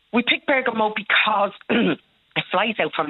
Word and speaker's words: Bergamo: Right. Bergamo. Is that how you pronounce it? – Bergamo, yeah. – Yeah. – That's it.